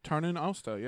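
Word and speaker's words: Tarnin Austa: Yeah, he has them time it up right as the Gondolindrum are all celebrating the festival of Tarnin Nosta. Did I say that right? Tarnin Osta. – Tarnin Osta. Yeah.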